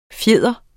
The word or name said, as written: fjeder